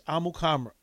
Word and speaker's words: Amukamara: The correct pronunciation for his last name and from his own mouth is Prince Amukamara.